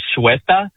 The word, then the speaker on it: Ceuta: It's the town of, and I believe I have the pronunciation here, Ceuta, C-U-E-T-A.